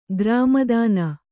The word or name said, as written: ಗ್ರಾಮದಾನ